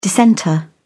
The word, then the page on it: dissenter: British English